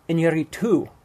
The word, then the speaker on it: Iñárritu: Is it Iñárritu? – Iñárritu.